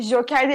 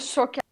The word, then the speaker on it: Şoker: O Joker değil bu arada Şoker. Doğru telaffuz çok önemli. Şoker diyorlar.